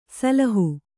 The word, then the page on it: ಸಿಲ್ಹ: silha